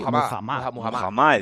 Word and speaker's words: Muhammad: Muhammad, he dicho. – Muhammad. No había oído nunca esa pronunciación de Muhammad.